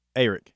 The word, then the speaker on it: Arik: So try to say it with a long A. – Arik. – See? – It sounds like Eric.